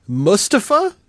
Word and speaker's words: Mustafa: Also on the WWE tip, I want to try to say this properly. Mustafa is apparently how it's pronounced.